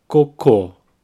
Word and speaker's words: cocô: So if you mispronounce coco and say cocô instead, you will be asking for poo water.